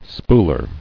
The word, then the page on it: Spooler: spool·er